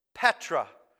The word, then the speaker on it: Petra: The word for rock here is the word, let me make sure I pronounce it properly, Petra.